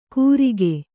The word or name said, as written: ಗುರಿಕೆ